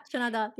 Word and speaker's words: chana dal: Yeah, chana dal. – Yeah. Oh, my word. And so sometimes we'll just, we won't even make the fried bread, like if it's really hot, but we'll make this chana dal, and it has all these amazing Indian seasonings.